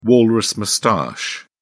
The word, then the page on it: walrus moustache: British English